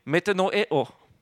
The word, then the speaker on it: metanoeo: It is pronounced, by the way, metanoeo.